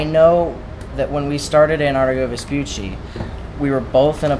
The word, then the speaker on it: Antarctigo Vespucci: Antarctigo Vespucci. – Yes, thank you for saying it right for me.